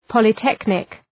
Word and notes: polytechnic: ,pɒlı’teknık

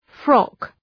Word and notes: frock: {frɒk}

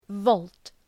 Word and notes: volt: vəʋlt

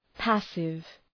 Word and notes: passive: ‘pæsıv

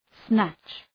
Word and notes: snatch: snætʃ

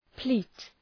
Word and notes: pleat: pli:t

